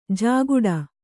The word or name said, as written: ಝಾಗಟಿ